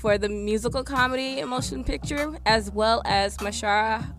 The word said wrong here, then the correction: Mahershala: I'm about to butcher this. – Mahershala Ali.